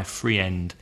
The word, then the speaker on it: friend: One common example is friend, which is F-R-I-E-N-D, which many Spanish people pronounce friend. – Yes. – My friend is coming out tonight. I'm going to meet my friend. But there's only one vowel sound in there, and it's e, friend. – Another one? – Meat. – M-E-A-T, meat.